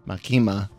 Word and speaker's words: Makima: Even though he notices that, did I say it right? – Makima, I would think is right. Makima. – Okay, there you go. Makima.